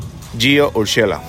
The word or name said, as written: Gio Urshela